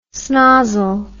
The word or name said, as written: snozzle